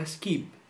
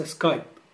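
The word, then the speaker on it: Skype: يعني مثلا كلمة سكايب وهي كلمة انجليزية في كتير من آلات النطق بتقولها سكيب وليس سكايب.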